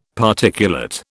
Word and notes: Particulate: British English

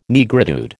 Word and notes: Negritude: American English

